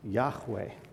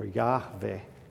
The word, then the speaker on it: Yahweh: There's no J in Hebrew anyway. It's YHW or VH. And if you add the vowel points that would go naturally with it, it's Yahweh or Yahweh, which is a modification of the Hebrew word to be.